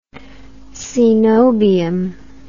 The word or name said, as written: cenobium